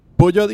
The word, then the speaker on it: Peugeot: Peugeot y Citroën, porque así es que lo pronuncian los franceses.